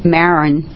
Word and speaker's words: Maron: My name is Danny Maron, M-A-R-O-N, Oliver.